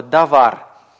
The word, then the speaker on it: давар: Если ударение падает на последний слог, как у слова «давар», допустим, вы заучили слово «давар», это обозначает слово, вещь, дело, «давар».